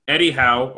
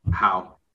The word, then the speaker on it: Howe: But I think what's more important to recognize is that Eddie Howe or Eddie Ho, how do you say his name? – Howe.